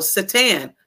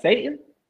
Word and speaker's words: Surtain: Surtain. – Patrick Surtain. – Staten. – No, it's Patrick Surtain, ain't it? Yes. – Is it Satan? – No, Surtain.